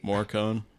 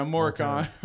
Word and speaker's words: Morricone: Morricone. – Yeah, Morricone.